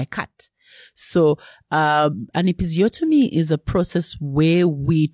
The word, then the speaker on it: episiotomy: Is it, and I might not even pronounce it correctly, an episiotomy? – Episiotomy, yes.